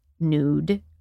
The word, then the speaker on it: Nuud: N-U-U-D. – Is that how we're saying it? – I believe so. It's Dutch. I don't understand Dutch pronunciation rules, but I think in the United States we pronounce it nude.